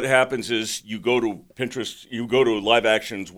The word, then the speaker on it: Pinterest: Are you saying Live, L-I-V-E, Action? – L-I-V-E, okay, Action. A place. They had a place on Pinterest. Is that the way you pronounce it? – Pinterest, right. – Pinterest. Did I say it wrong? – No, Pinterest. Pinterest. You're right.